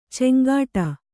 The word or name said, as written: ಚಂಗಾಟ